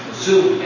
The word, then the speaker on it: Sioux: How do you pronounce it? – Sioux. S-I-O-U-X.